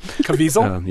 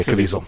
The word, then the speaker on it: Caviezel: But Person of Interest is a kind of Minority Report style show, starring Jim Caviezel, however you say his name. – Caviezel. – Yeah, Caviezel. Thank you.